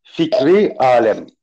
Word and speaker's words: Fikri Alem: It's F-I-K-R-I-A-L-E-M. – Yeah, correct? – right. Yes, right. – Oh, good. – And if they want to pronounce this name is Fikri Alem.